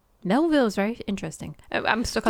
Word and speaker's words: Melville: So, Melville Macken... I looked at this the other... last night. – Melvin? – No, it's Melville. – Oh, Melville.